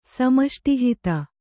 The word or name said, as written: ಸಮಷ್ಟಿಹಿತ